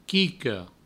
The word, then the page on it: quica: provençal; quico